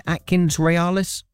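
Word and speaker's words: AtkinsRéalis: Have I said that right? Is that right, Atkins Realis? – That's perfect, Richard.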